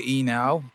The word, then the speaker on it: Enow: Enow. – Yeah, Americans would say Enow, and back home they would be like Stanley Eno, Eno.